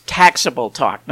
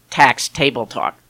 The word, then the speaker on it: Taxable Talk: It's Taxable Talk, not Tax Table Talk.